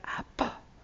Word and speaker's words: Abba: And it's pronounced as Leif Hepburn, A-B-B. It's like, Abba, Abba.